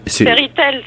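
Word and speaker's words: Fairy Tale: Dis-moi, est-ce que je le prononce bien, parce que je ne voudrais pas non plus écorcher. – Fairy Tall, c'est très bien.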